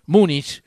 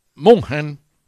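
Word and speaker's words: München: Bayern Múnich ante el Borussia Dortmund, no se pronuncia Múnich, sino Munchen, pero bueno, lo pronunciamos Múnich.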